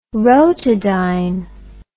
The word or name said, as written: rotodyne